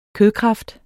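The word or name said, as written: KØDKRAFT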